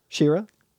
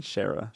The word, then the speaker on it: She-Ra: She-Ra. – She-Ra? I was saying Shara. – She-Ra is how it's spelled. She-Ra? – She-Ra. – It's spelled like She-Ra minus the hyphen.